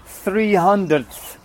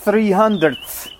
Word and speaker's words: 300th: Really hard to say. 300th. Because there is double th sound at the very beginning and then at the end as well. So it's quite tough to pronounce. 300th.